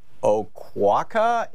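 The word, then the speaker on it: Oquawka: And Larry in Oquaka, Illinois, am I saying that right, Larry? – Yes, sir. Yes, sir.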